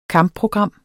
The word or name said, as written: kampprogram